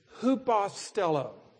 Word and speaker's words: hupostello: It's hupostello. You might spell that H-U-P-O-S-T-E-L-L-O. H-U-P-A-S-T-E-L-L-O, hupostello.